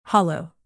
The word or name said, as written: hollow